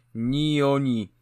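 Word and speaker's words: Nioh: Nio, a po drugie, a po drugie, po drugie to jest gra japońska, a dwa po japońsku to ni, więc powinno się ten tytuł wymawiać ni oni.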